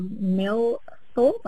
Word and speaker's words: Millthorpe: just outside of the tiny gold rush village of Millthorpe, I hope I pronounced that right, yep.